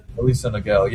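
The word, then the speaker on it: Aliso Niguel: You were taken 53rd overall just last year in 2025 out of, and excuse me if I butcher the name, is it Elisa Niguel High School at Elisa Viejo, California? – Elisa Niguel, yeah.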